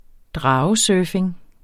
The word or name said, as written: dragesurfing